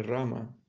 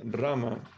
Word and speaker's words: Rama: Uno de los hermanos espirituales, de sus hermanos espirituales, me dijo que cuando cantamos Hare, Hare Rama, en el Maha Mantra, no se supone que pronunciemos Rama. Él dice que esto es un Shuddha puro, sino no es un Shuddha puro, sino que deberíamos decir Ram. ¿Es esto correcto? Sí, Almajara dice, Rama es la pronunciación verdadera. Pero si uno dice Ram, ello no implica que no tiene valor, tendrá algún efecto.